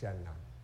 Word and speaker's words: Siena: But what caught my attention when I started looking at the liturgy is today's saint, Saint Catherine of Siena, or I think it's pronounced Shenna in the Philippines, like Shenna College.